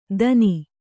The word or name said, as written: ದನಿ